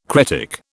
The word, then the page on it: cretic: British English